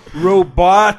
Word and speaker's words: Robotech: He's like, no, Robotech.